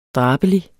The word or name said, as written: drabelig